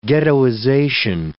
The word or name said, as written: ghettoization